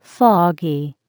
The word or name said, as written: foggy